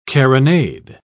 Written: carronade

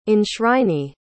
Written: enshrinee

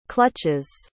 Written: clutches

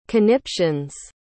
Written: conniptions

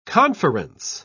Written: conference

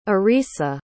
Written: arisa

